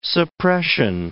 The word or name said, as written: suppression